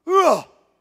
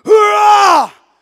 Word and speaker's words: HUA: It's spelled H-U-A. Hoorah! Who? Sister who? Hoorah! H-U-A.